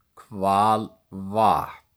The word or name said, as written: Hvalvatn